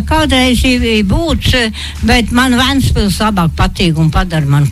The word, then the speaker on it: Ventspils: Kā tad jāizrunā īsti? Plato vai Šauro? – Nav nekāds Šaurais E. Ir Venta un Ventspils.